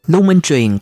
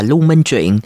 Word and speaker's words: Lưu Minh Truyền: Lưu Minh Truyền còn đọc là Lưu Minh Truyện,